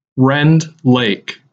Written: Rend Lake

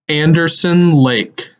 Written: Anderson Lake

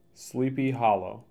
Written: Sleepy Hollow

